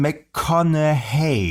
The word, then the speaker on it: McConaughey: Also es gibt, viele Leute sagen McConaughey, ja, ja. Andere sagen McConaughey, ja. Und so richtig soll es wohl sein McConaughey.